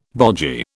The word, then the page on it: bogy: British English